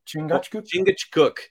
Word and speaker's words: Chingachgook: Chingachgook? – Chingachgook.